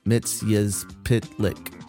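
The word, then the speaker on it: Mxyzptlk: Mr. Mixelplix, pronounce Mitzyaz Pitlick. in Superman, the animated series. He reprised his role as, I thought it was Mixelpix. He reprised his role as Mitzyaz Pitlick that's how it's spelled out phonetically.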